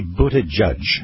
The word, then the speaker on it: Buttigieg: Buttigieg. – It's actually Buttigieg.